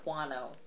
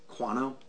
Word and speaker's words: Kuano: I'd say Kuano. – Kuano? Like Guano? Sure. – Well, it's spelled like Guano, but with a K.